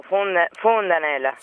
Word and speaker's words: Fondanella: Con la O, Fondanella.